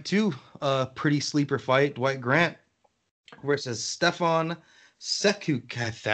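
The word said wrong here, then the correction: Sekulić: I don't think I said that right. – Sekulic.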